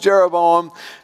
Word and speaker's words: Jeroboam: And early in his kingdom, he's approached by what you might call a labor union leader from up north, whose name actually rhymes with Rehoboam. It's Jeroboam. And so Jeroboam comes and he says, King, look, your father, he taxed us too much.